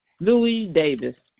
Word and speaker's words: Louise Davis: Louis Davis.